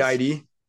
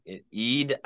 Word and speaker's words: Eid: Eid. – Yeah, okay. Eid, I was going to say the whole thing.